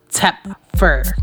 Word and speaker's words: Tepfer: This is an article from CT Post by Daniel Tepfer. That's how you say it, Tepfer. T-E-P-F-E-R.